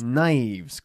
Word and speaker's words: knives: Knives, con una K muda.